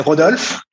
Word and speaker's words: Rodolphe: In French, we would say Rodolphe.